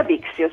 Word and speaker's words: Bixio: Intanto ho detto giusto, via Bixio? – Quella sì, via Bixio, sì, sì, sì, una via storica di Parma.